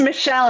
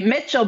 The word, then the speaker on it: Michell: Is it Michelle or Mitchell? How do I pronounce it? – It's Michelle, and I can't believe you got it right. So many people get it wrong, and they say Mitchell, but there's no T, but thank you.